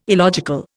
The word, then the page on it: illogical: Indian